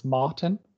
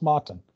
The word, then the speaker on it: Morton: Well, it's Martin. – Martin. – God. But someday you'll get it right. Don't worry about it. It's spelled Morton. It's just pronounced Martin. So it's okay.